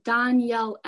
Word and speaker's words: Dahnielle: I have my name as Dahnielle M, and my name is spelled weird. It's pronounced weird too, I think. And so it's spelled Dahnielle, and then M.